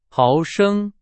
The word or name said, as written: 毫升